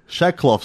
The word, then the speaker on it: Shackcloth: Hobart man Tom Shackleclough. – What is that? – Shackcloth, sorry. – hanger. Oh, how do you spell it? – S-H-A-C-K-C-L-O-T-H.